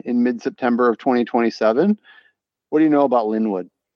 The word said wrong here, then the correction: Lindenwood: I know that they're pronounced Lindenwood. – Oh, yeah, sorry. My bad.